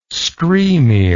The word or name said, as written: skrymir